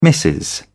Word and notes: missus: British English

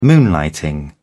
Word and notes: moonlighting: British English

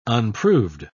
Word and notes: unproved: British English

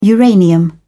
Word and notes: uranium: British English

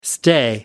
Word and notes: stay over: American English

